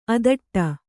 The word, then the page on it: ಅಧಟು: adhaṭu